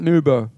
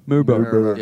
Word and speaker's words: Maribor: The more people I talk to, I like it because a lot of people that don't know Maribor will say, oh, Maryborough. But the more people that talk to me go, oh, you have to pronounce it like a... fucked like that. Maribor. Maribor.